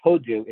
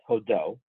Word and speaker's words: Hodo: Odo Ezra Shemayim, and in case you're pronouncing it, it's not Hodu, it's Hodo. Hold on, there's an O there, don't get that wrong.